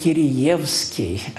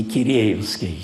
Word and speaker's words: Киреевский: «у нас принято говорить Кириевский, а не Киреевский». Я бы открываю философскую, смотрю, два ударения. Ну ладно, предпочитаю следовать традиции.